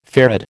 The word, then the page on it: farad: American English